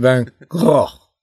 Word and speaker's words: Van Gogh: Simon, one of the things I'm curious about is I've sometimes heard his name pronounced not Vincent Van Gogh, I'm starting already, but Vincent Van Gogh, with much more of a guttural sort of, which might be relevant because obviously he's from Holland. They have some guttural sounds there.